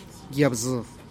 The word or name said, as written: yab zu